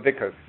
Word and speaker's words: Vicus: By the way, am I pronouncing it right? Is it Vicus? – Yeah. Well, Vicus, yeah. – Vicus. – Vicus. Vicus. – It's Latin.